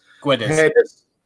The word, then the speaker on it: Guedes: Guedes.